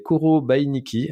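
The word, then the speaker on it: Korobeiniki: Ça, c'est Koro Bainiki, hein, comme ça se prononce. K-O-R-O-B-I-N-I-K-I.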